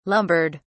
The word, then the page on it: lumbered: British English